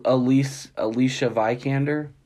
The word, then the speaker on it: Alicia Vikander: Oh, Alicia Vikander? – Yeah, that sounds more like it.